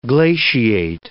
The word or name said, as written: glaciate